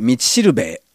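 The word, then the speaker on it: 五稜星: 今回はコナン 100 万ドル道しるべ、あ、五稜郭の五稜に星と書いて道しるべと読むんですね。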